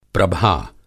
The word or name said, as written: प्रभा